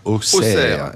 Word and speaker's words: Auxerre: Ça s'écrit Auxerre et ça se prononce Auxerre. – Auxerre. – Et les Bourguignons ont toujours eu un problème avec les X.